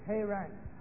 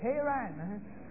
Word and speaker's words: Haran: Hebrew pronounce it Haran. So let's pronounce it Haran.